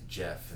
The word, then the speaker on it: Geoff: My name's Geoff. – And I'm going to ask you, because I need to know the backstory, your nickname is Jihad. – Jihad. My name is Geoff, and it's spelled with a G. – Oh, G-O-F. G-E-O-F-F.